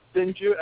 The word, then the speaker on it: Sinju: Yes, it's Pete, and last name is Sinju, S-I-N-J-U. – Okay, so Pete as in P-E-T-E, right? – Yes. – And then Sinju, S-I-N-J-U? – Correct.